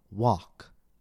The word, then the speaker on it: walk: For example, the spelling pattern A-L-K, as in the words talk, walk, and chalk. In this spelling pattern, the L is usually silent.